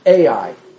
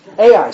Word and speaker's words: Ai: Ai. – That's the way I say it too. Right. Ai.